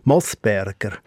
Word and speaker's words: Moosberger: Mundartlich wird der Name mit kurzem O ausgesprochen, Moosberger. Und es geht dann auch mit einem O geschrieben.